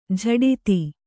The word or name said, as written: ಝಡಿತಿ